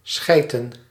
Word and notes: schijten: /ˈsxɛi̯.tə(n)/

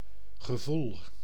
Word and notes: gevoel: /ɣəˈvuːl/

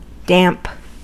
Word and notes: damp: [ˈdæmp]

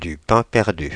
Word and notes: pain perdu: Paris; /pɛ̃ pɛʁ.dy/

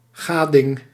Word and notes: satisfaction: American English; /sætɪsˈfækʃən/

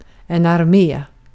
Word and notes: ordu: turkiska

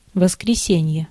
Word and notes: воскресенье: [vəs.krʲɪ.ˈsʲenʲ.jə]; voskresenje

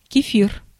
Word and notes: кефир: /kʲɪ̝ˈfʲir/; kefir

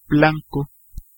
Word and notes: blanco: /ˈblaŋko̞/; /ˈblan.ko/

